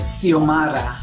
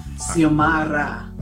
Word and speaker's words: Xiomara: It's Xiomara. – Xiomara. It's got a like a... – Right, the X is silent, mm-hmm. – and it's Ra, Xiomara.